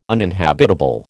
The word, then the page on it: uninhabitable: American English